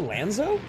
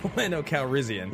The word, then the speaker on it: Lando Calrissian: Did you say Lanzo? – Lando Calrissian.